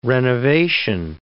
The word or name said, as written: renovation